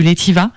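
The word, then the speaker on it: L'Etivaz: Par contre, j'ai vécu une expérience dans les alpages suisses en fabriquant du Letiva. Alors, ça s'écrit E-T-I-V-A-Z. Mais le Z ne se prononce pas.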